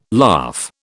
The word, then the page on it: laugh: British English